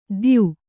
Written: ದ್ಯು

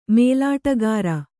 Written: ಮೇಲಾಟಗಾರ

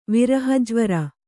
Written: ವಿರಹಜ್ವರ

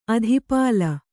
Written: ಅಧಿಪಾಲ

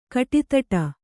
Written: ಕುಡುದಡಿ

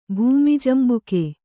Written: ಭೂಮಿಜಂಬುಕೆ

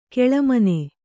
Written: ಘುಳುಮ್ಮನೆ

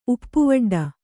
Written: ಉಪ್ಪುವಡ್ಡ

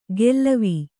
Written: ಗೆಲ್ಲವೀ

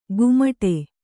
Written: ಗುಮಟೆ

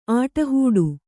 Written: ಆಟಹೂಡು

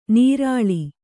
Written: ನರಳು

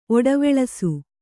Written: ಒಡವೆಳಸು

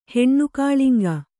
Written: ಹೆಣ್ಣುಕಾಳಿಂಗ

